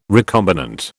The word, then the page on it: recombinant: British English